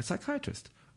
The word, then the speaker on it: psychiatrist: And I went, a psychiatrist? with a silent P.